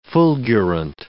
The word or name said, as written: fulgurant